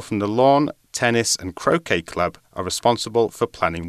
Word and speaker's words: croquet: Wimbledon has been organised by the All England Lawn Tennis and Croquet Club since 1877. So, Croquet Club, not Croquet Club, because it's spelled C R O Q U E T. So, it's a, a tricky word there.